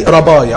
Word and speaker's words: rabbi: لا، ده هو كان يقصد ربي، لو كتبناه بالانجليزي R-A-B-B-I اللي هي بتنطق زي رباي، عارفين لقب رباي؟